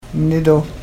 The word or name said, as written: mnidoo